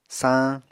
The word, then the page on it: saañ: Apatani